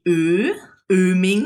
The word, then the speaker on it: Oeming: But how's the proper pronunciation? – It's actually Oeming. – Oh, Oeming. Oh, so we have an umlaut. – We don't, but it does sound like an umlaut.